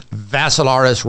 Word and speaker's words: Vassilaris: Picture a cyclops, picture a second cyclops, and then picture a third cyclops, D-I-M-I-T-R-I. Three cyclopses. Cyclops I? Well, there are three of them. The last name is Vassilaris, rhymes with Assilaris, except you had a V.